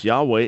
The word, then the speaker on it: Yahweh: As far as the divine name, like I said, yeah, it's pronounced Yahweh, and it's originally a verbal form, and there's really not a lot of dispute about it among most scholars.